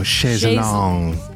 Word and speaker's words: Chaise Longue: And you figured out how to pronounce that other single. – Oh, Chaise Longue.